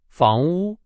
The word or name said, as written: 房屋